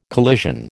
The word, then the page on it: collision: American English